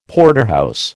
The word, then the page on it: Porterhouse: American English